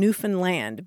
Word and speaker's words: Newfoundland: They say, think of understand and Newfoundland because they get... – Gotcha. – They're not happy. – It's not Newfoundland.